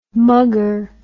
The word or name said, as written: muggur